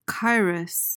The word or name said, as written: kairos